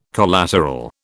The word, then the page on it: collateral: British English